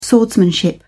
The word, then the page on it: swordsmanship: British English